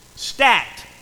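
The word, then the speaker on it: stacte: Stat is the way that word is pronounced.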